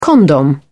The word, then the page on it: condom: British English